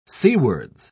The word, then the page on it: seawards: British English